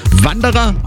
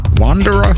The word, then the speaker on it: Wanderer: Wanderer, auf Deutsch ausgesprochen. Wanderer, wie auch immer, auf Englisch.